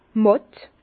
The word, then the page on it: màtt: Bas-Rhin; Herrlisheim